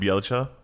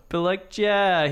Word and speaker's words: Bjelica: Belitsia, what's his name? How do you say that? – Nemanja Bjelica. – Bjelica, he could be good.